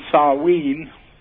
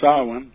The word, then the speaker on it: Samhain: Well, it's Halloween, Samhain, or Samhain. – Yeah, what's that? That's a Gaelic word for. – Yeah, it's pronounced different ways by different tribes, and it's actually, you know, the 31st is Eve, the eve of Samhain.